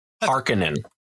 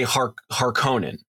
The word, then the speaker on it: Harkonnen: Harkonnen. – So here's the thing. – So in the 84 movie, they say Harkonnen. – Yeah. – But it should be pronounced Harkonnen.